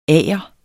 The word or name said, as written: agers